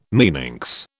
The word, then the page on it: meninx: British English